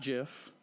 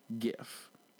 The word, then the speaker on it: GIF: The correct answer is GIF. Not JIF, GIF.